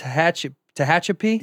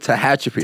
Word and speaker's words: Tehachapi: Well, what, you grew up, Nick told me, I can't even pronounce it, I tried 6 times. – You got it. – Tehachapi? – Yes, sir. – The first Tehachapi.